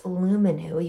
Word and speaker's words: Luminux: my new favorite whitening strips, Luminu. You can find them on Amazon. It's spelled Luminux, and they're certified non-toxic whitening strips, and they really, really work without leaving your teeth and your gums super sensitive.